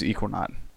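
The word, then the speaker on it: Ekuanot: So it's probably Equinot. – It's phonetically spelled out, yes. It is Equinot.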